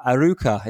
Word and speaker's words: Arouca: Uh, Arouca, is that how you spell it? Is that how you pronounce it? It is indeed.